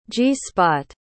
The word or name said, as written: g-spot